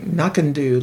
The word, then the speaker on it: Knockando: Well, when I looked up the pronunciation, it was sort of like Knockando, like no can do.